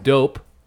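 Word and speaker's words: Doughp: Dough with a P at the end, pronounced dope.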